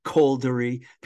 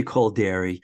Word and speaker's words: Kolderie: Caldery. People always say his name differently. I say Kolderie uh, at Camp Street Studios in Cambridge.